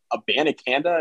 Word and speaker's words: Abanikanda: Um, I I do like, and, and I make fun of Pitt's running game a lot, but I like uh, Abanikanda. Am I pronouncing his name right? Did I get it right? Yes, you are.